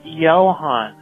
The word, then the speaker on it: Johan: You said Johan. And it's pronounced Johan. – You don't know how it was spelled. It was spelled weird. – If it's a German name, the J makes a yes sound.